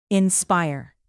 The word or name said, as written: inspire